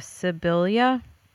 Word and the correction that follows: Sibilia: S-I-B. I-L-I-A. That's three eyes. Sibilia.